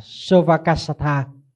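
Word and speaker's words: sovacassatā: trong kinh đọc là sovakasatha tức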